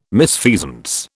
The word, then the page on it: misfeasance: British English